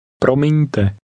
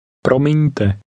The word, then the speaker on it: Promiňte: Promiňte. Promiňte.